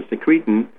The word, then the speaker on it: secretin: For example, secretin, or sometimes pronounced as secretin, was a substance that was being injected into children around the mid-1990s, late 1990s.